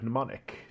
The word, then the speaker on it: mnemonic: Saint Greek root is the word mnemonic. The M is silent.